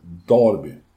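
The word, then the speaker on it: derby: travet har sitt travdarby, men galoppen har sitt darby. – Mm. Ja, men särskilt i galoppvärlden är det väldigt noga med ja. uttalet darby. Ja, och framförallt inte galoppdarbyt utan darbyt.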